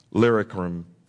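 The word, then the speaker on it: Illyricum: so that from Jerusalem and around about till Lycurium, I have fully preached the gospel of Christ." Lyricum is the proper pronouncing.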